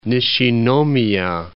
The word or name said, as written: Nishinomiya